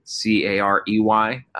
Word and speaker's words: Carey: Carey, and my last name is Carey. Being around baseball, I get to do the correction that my name is not spelled like Harry Carey's all the time.